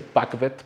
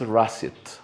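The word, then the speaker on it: Rassvet: Eh, eh, se llama Rassiet. Mucha gente le dice Pakvet porque así se escribe la latinización de esta palabra en rusa, pero es Rassiet, que quiere decir amanecer,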